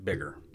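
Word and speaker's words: Biggar: And it's B-I-G-G-A-R, but everybody pronounced it Bigger.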